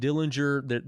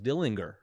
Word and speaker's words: Dillinger: But I really can't answer you as to why the fascination, other than, you know, just Dillinger.